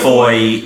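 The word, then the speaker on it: Fowey: He pronounced it Foy. And it's pronounced Foy, like Claire Foy, I've been told.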